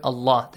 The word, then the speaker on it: Allah: The lam in the word Allah must be pronounced heavily. Allah. Not Allah. No. Yes. So, ya'lamhu Allah. Because you have a dhamma sound before. If you have a dhamma sound, a fatha sound before the word Allah, the letter lam is pronounced with a heavy sound.